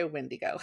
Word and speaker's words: Wendigo: Do you pronounce it Wendigo or Weendigo? – So that's tricky because everybody pronounces it different. So I'm going with Wendigo right now.